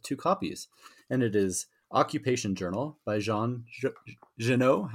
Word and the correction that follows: Giono: I hope I'm saying that right. Is that how you say it? Oh, Giono? – yeah, something like that.